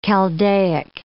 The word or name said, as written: Chaldaic